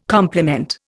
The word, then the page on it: complement: Indian